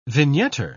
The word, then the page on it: vignetter: British English